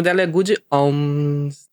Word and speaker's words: Omens: Aliás, eu não sei a pronúncia direito. Como é que é, Igor? É Omens? Como é que é? Good Omens? The Good Omens. – Uh -huh. Omens.